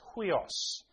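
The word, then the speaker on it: huios (υἱός): For those that are interested, it's spelt U-I-O-S. but you have to put a hut at the start. So it's huios.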